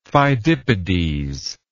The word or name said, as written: pheidippides